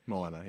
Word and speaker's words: Moana: She pointed out modern princesses like Moana, is that how you say it? – Moana, yeah.